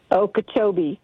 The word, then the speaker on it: Okeechobee: Picking up your calls here in Okeechobee, Florida. Am I saying that right? – Okeechobee.